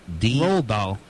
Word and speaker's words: Roald: And yeah, she was married to a British writer, Ronald Dahl. – Ronald. – D-A-H-L. Dahl. Ronald. Oh. – Hey, Roald. – Roald. How the heck do you pronounce it? R-O-A-L-D. – Roald Dahl. – D-A-H-L.